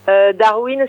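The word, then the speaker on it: Darwyne: Darwin, ça s'écrit D-A-R-W-Y-N-E, c'est un prénom.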